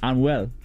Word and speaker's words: Anuel: What about Anuel and Bad Bunny? – Nah, wait. First of all, you didn't say the guy's name right. Anuel.